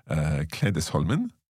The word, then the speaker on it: Klädesholmen: We've up in Marstrand and Klädesholmen.